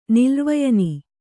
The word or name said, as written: ನಿಲ್ರ್ವಯನಿ